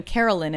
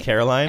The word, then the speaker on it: Caroline: I think it's not even Carolyn in the City. – Is it Caroline? – I think so.